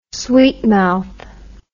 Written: sweetmouth

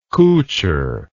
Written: kootchar

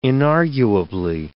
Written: inarguably

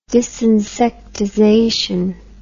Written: disinsectization

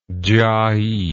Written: chiayi

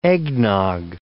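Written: eggo

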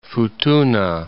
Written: Futuna

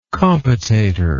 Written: compotator

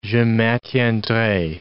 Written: je maintiendrai

